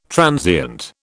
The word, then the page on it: transient: British English